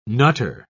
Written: nutter

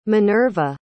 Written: manerva

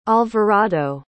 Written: alvarado

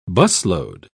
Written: busload

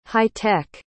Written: hightech

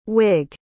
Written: whig

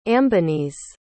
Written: ambonese

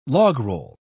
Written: logroll

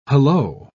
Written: hallo

